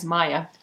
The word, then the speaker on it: Maja: I have done a lot of word pronounce, clicked on the YouTube link, so yes, because I don't like things butchered, because my name, four letters, M-A-J-A, people pronounce it all different ways, but the name is Maya.